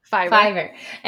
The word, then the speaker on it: Fiverr: Fiverr. What is it? – Fiverr.